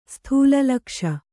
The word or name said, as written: ಸ್ಥೂಲಲಕ್ಷ